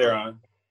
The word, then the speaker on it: Theron: I might be saying that wrong. – It's Theron.